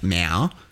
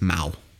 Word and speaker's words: Mao: I say Mao, which apparently is wrong. It's Mao.